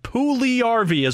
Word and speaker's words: Puljujärvi: I pulled this off the NHL pronunciation. Puljujärvi is what they have.